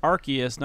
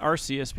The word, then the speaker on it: Arceus: Also, did you know that it's pronounced Arceus, not Arceus, because Arceus sounds like arse. They literally changed it because of that. It sounds like arse, like ass.